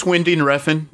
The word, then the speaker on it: Winding Refn: Winding Refn. I'm probably butchering that like crazy. – It's Winding Refn, but that guy, it's the same shit.